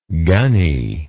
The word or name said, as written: gunny